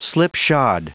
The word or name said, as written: slipshod